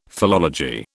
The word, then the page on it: philology: British English